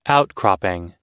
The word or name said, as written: outcropping